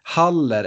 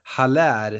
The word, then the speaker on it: Haller: Men Haller, eller Haller som jag kanske har förstått att det ska uttalas nu, fransman som han är.